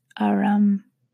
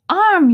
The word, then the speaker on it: arm: He pronounced it arum. – "An arm, you goose!